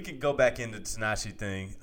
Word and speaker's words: Tinashe: Anybody that sings that many things, you don't say his name the right way. He's Tinashe. – Tinashe. – You got to make it a little softer.